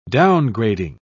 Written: downgrading

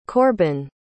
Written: korbyn

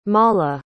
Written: malha

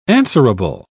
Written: answerable

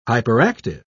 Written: hyperactive

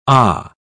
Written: aah

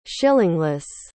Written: shillingless

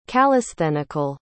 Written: calisthenical

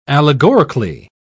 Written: allegorically

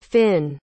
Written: fynn

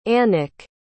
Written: anik

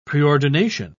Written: preordination

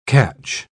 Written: catch

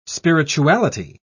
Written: spirituality

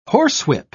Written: horsewhip

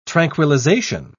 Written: tranquilization

